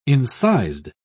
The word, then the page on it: incised: British English